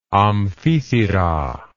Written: amphithyra

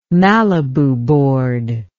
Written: Malibu board

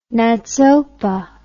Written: NATSOPA

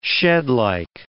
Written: shedlike

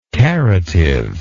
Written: caritive

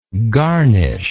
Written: garnisher

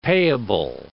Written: payable

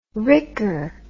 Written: ricker

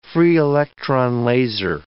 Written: free-electron laser

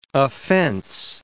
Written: offense

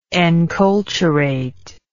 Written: enculturative

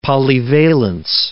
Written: polyvalence

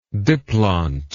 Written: diplontic

